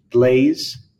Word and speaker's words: Blaise: I believe his first name is pronounced Blaise, B-L-A-I-S-E, Pascal.